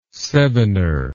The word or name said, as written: sevener